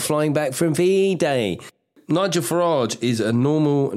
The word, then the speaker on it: Farage: And David said, Farage is a Norman French surname, hence the pronunciation.